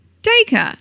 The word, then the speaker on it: Dacre: Is it Dacre? Dacre. – Okay. I never pronounce it right because I read it. – It's like acre with a D.